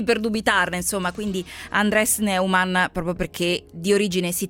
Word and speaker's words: Neumann: Sì, alla tedesca si pronuncia Neumann, ma siccome lui è spagnolo, mi ha spiegato Alessandra Tedesco, si fa chiamare alla spagnola Neumann.